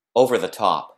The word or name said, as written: over the top